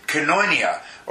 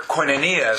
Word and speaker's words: koinonia: But certainly at the beginning part of last year, when we were teaching about koinonia, or koinonia, some might pronounce it, that term, biblical fellowship, we started to get some understanding that, you know, maybe we're just a little or a lot different.